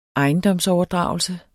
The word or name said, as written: ejendomsoverdragelse